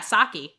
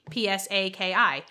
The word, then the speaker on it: Psaki: And he said to me, do you know how to pronounce the White House press secretary's last name? And I said, yeah, Psaki, Psaki. And he goes, oh, I didn't know that because I just read about it.